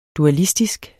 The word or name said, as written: dualistisk